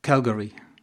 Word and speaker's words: Calgary: You've been to the University of Calgary.